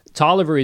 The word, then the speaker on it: Taliaferro: Now, for anyone taking notes, Tolliver is spelled exactly like it sounds, T-A-L-I-A-F-E-R-R-O.